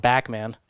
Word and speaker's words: Bachman: But I did talk about his – he had another singer with him, and it was Bachman. – Yeah. – But he called him Backman. That's so right. So I guess in Canadian, they pronounce it a little differently.